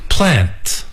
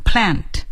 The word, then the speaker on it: plant: Plant. – Plant. 大家听出来 是美国人，读的是美式的发音 plant, 那要是第一个 plant, 那是英式的发音 plant, 无论是 plant and plant 都是正确的。